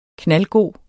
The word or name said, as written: knaldgod